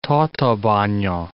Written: Tatabanya

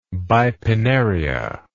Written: bipinnaria

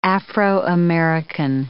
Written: Afro-American

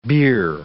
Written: beer

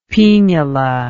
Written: paenula

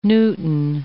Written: Newton

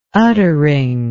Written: uttering

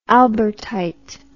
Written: albertite

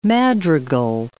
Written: madrigal

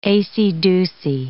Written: acey-deucy

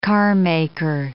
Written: carmaker

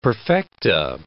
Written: perfecta

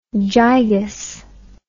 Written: gigas

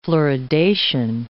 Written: fluoridation

